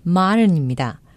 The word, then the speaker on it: modern: 저는 modern, M-O-D-E-R-N, modern, 현대, 신식의 그런 뜻이죠. 현대적인 분위기나 세련된 분위기를 모던하다라고 표현하는 분들 계십니다. 정확한 영어 발음은 modern입니다. modern.